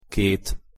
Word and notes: ket: Hungarian

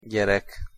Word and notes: gyerek: Hungarian

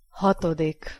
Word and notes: hatodik: Hungarian

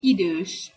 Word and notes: idos: Hungarian

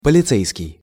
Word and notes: policejskij: Russian

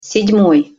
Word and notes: sed-moj: Russian

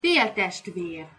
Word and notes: feltestver: Hungarian

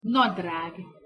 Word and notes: nadrag: Hungarian

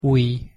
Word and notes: ujj: Hungarian

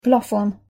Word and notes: plafon: Hungarian

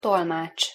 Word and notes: tolmacs: Hungarian

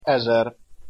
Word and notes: ezer: Hungarian

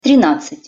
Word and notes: trinadcat-: Russian

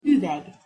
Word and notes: uveg: Hungarian

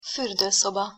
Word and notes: furdoszoba: Hungarian

